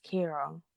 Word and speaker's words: Ciara: But I don't know, because it's spelled C-I-A-R, C-I-A-R-A, but pronounced Kira.